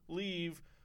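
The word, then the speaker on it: Leav: Peter Leave, that's how you pronounce it, Leave, was the one who took over.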